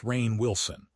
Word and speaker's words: Rainn Wilson: Rainn Wilson, pronounced Rainn Wilson, argues that this dissatisfaction is not a failure of achievement, but a failure of inquiry.